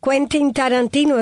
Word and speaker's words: Quentin Tarantino: Quentin Tarantino. ¿Es así? ¿Cómo se pronuncia, Teudy? – Sí, es así. – Quentin Tarantino.